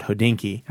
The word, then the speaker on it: Hodinkee: You were previous to Bloomberg, you were at a website called Hodinkee.